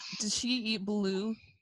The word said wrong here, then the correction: balut: Oh, balut, you mean? – Because that's a thing, right? – But yeah, it's pronounced balut.